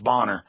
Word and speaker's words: Boner: It's spelled Boner, but it's pronounced Bonner.